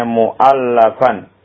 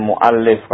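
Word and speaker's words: مُؤَلَّفًا: Perhatikan bacanya mu'allafan. Isim maf'ul ya, bukan mu'allif.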